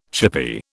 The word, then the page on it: chippie: British English